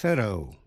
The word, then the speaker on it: thorough: Bài trước chúng ta đã học chữ thorough, T-H-O-R-O-U-G-H, có hai cách phát âm. Thorough, đọc theo giọng Anh. Thorough, đọc theo giọng Mỹ.